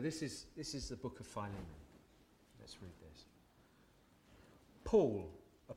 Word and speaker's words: Philemon: Now I looked up how to pronounce it, and I read one of those little sound bites that you can click on the internet, and it's Philemon.